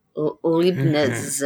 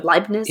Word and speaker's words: Leibniz: Well, another major contributor to classical physics was Gottfried Wilhelm Leibniz. Is that how you say it, James? – Exactly. Yep. – Leibniz? – Yeah.